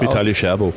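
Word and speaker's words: Vitaly Scherbo: Der belarussische Kunstturner Vital Tscherba. Wir haben neulich noch drüber gesprochen. Ich weiß nicht, du kennst es mit der Aussprache ein bisschen besser. – Vitali Tscherbo. – Tscherbo, siehst du.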